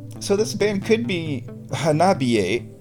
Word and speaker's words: Hanabie: The band's name is Hanabi with an E at the end. Which in Japanese would be pronounced Hanabie.